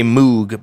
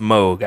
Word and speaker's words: Moog: A lot of people say Moog, but it's actually pronounced Moog. I asked the people at the company.